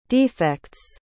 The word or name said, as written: defects